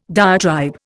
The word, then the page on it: diatribe: Indian